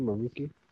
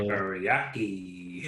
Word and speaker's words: Meraki: It's, I want to say Meraki. – Yeah, Meraki, Maruki. – Like teriyaki? – Yeah. – Meraki.